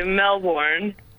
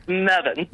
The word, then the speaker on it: Melbourne: It's because we have a hard time pronouncing the word Melbourne. We say Melbourne. And you guys are like, no, it's Mevin.